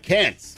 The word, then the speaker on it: Cannes: Cannes. Cannes Film Festival. – It's no S at the end. It's spelled with an S.